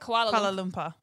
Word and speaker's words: Kuala Lumpur: Kuala Lumpur.